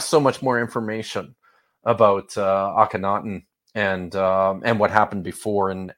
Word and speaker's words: Akhenaten: He's often called Akhenaten. – Yeah, Akhenaten. – That's Akhenaten. There it is.